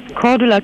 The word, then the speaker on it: Cordula: Cordula Kienes. – Oh, wie schreibt man das? Cordula jetzt hier? – Mit C. – Zeppelin oder was? – Nee, C wie Cäsar. – Ja, weiter. – C-O-R.